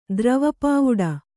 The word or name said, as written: ದ್ರವಪಾವುಡ